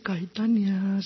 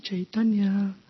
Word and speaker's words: Chaitanya: He goes, no se dice Caitanya, se dice Chaitanya.